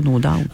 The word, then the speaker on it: No Doubt: Ben, je sais que je le prononce mal, Daub. Là. Je sais pas si je le prononce bien ou je le prononce mal. C'est No Doubt. Mais ça, on a toujours dit No Doubt.